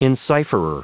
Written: encipherer

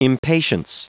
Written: impatience

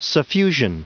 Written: suffusion

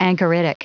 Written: anchoritic

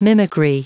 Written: mimicry

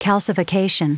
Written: calcification

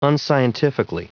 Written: unscientifically

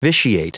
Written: vitiate

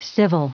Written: civil

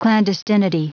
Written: clandestinity

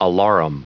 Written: alarum

